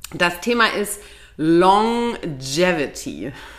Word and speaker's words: Longevity: Longevity.